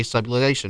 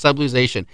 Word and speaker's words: subluxation: if medically necessary to correct a subluxation. And I may not pronounce that right, but it's subluxation. – Subluxation. – So there we go. There is the correct pronunciation.